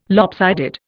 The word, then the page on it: lopsided: Indian